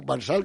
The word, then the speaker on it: Marsal: Te voy a decir Marsal con acento en la L.